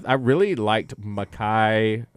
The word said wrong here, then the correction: Malachi: Am I saying this right? Singleton. – Malachi Singleton. Malachi Singleton.